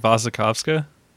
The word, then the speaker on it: Wasikowska: Are the W's in her name V's, or are they... – That's how everybody pronounces it, so... – Wasikowska? – Wasikowska. – So the second W is a W.